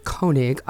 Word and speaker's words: Koenig: And we are really happy to welcome to the show today pianist Robert Koenig,